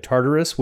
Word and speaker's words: Tartarus: You got the idea of Tartarus, which is the ship in Doctor Who. – Oh, no, that's the TARDIS. – Oh, never mind. – This has got an extra syllable.